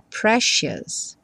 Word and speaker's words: precious: Repara que eu botei um X em cima desse C, porque a pronúncia é precious.